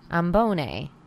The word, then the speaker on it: Ambonnay: It's the same word. It's repeated twice. Ambonnay. Ambonnay. D'Ambonnay.